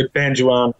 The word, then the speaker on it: Banjawarn: I stayed up very late last night and I have just finished reading your debut novel, Banjoan. Now, did I say that correctly? – That's how I say it, but since the novel's come out, I've heard like 15 different pronunciations. I tend to say Banjoan.